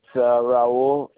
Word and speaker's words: Raul: It's Raul. It's spelled R-A-U-L. And the last name is Feliciano. And that's spelled F-E-L-I-C-I-A-N-O.